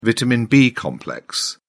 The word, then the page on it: vitamin b complex: British English